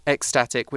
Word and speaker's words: ecstatic: Um, so it's E C S, but we pronounce that as X ecstatic, which means really, really happy.